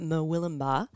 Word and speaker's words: Murwillumbah: Murwillumbah. – How do you say that? – Don't make me do it again. – That was beautifully done.